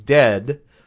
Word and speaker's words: Dehd: And the album is, it's called Water, and it's from the band Dead, although Dead is spelled D-E-H-D.